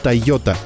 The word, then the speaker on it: Toyota: Фамилия основателей звучит как «Тойода». Для упрощения произношения и в качестве символа отделения деловой деятельности от семейной жизни компании было решено дать имя «Тойота».